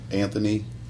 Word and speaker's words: Anthony: Anthony, Sr., J-O-H-N-N-Y. Middle initial is Lee, and my last name spelled is Anthony, A-N-T-H-O-N-Y.